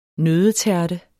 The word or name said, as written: nøddetærte